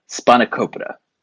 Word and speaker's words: spanakopita: So, yeah, spanakopita.